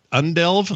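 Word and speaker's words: Udelv: And Walmart has partnered for delivery pilot programs with Undelve. – U-delve. – Am I saying that right? I think U-delve. – So. Undelve? – U-delve. – Undelve. – Like you. – U-delve. Yeah, like me, like you.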